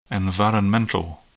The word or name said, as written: environmental